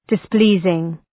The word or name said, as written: displeasing